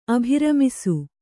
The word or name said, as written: ಅಭಿರಮಿಸು